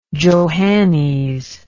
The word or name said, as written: johannes